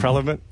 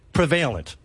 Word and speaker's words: prevalent: Prevalent.